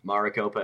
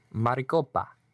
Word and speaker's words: Maricopa: Maricopa, Arizona. – Maricopa.